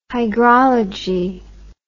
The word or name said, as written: hygrology